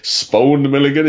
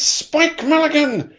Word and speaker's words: Spike Milligan: He's going, oh, Spone Milligan, is it Spine Milligan? Is it Spoke? Some stupid name like that. And he goes, it's Spike Milligan!